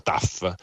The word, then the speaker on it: tough: Erano persone di aspetto normale, ma tough, che si pronuncia tough, e vuol dire che puoi pestarlo finché vuoi.